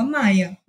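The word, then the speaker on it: Meyer: Olha, na realidade, quando minha minha família fala, eles falam Camila Maia, mas eu também sempre tive essa dúvida, para falar a verdade.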